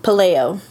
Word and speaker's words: Pelayo: So, the Franklin County cold case is about 13-year-old Anna Pelayo. – Pelayo? – Pelayo. – Pelayo? – Pelayo. I'm pretty sure.